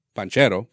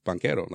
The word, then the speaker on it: Banchero: The way it's spelled, it should be pronounced panchero. – But, panchero. – But like, I think even when he was drafted, he was like, "No, it's it's banquero."